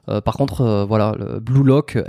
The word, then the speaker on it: Blue Lock: Blue Lock. Ouais, d'accord. – Alors, il y a Blue Lock, euh, bah, comme ça se prononce en anglais.